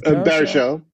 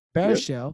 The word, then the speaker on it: Baruchel: Is Baruchel. – Baruchel.